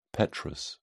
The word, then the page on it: petrous: British English